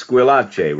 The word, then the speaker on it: Squillace: But it's Squillace, right, Frank? – Yes, it is.